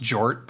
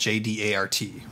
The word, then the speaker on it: J-DART: I prefer to think it's pronounced Jort, but his lawyer created an acronym called J-D-A-R-T, right? – Yeah, it's pronounced J-Dart.